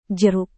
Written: jeruk

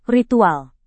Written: ritual

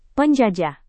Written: penjajah

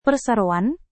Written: perseroan

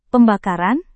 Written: pembakaran

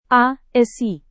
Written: ASI

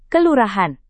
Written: kelurahan

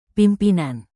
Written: pimpinan

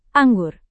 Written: anggur

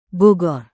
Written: bogor

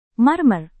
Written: marmer

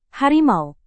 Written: harimau

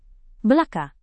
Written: belaka

